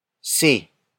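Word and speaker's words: si: See.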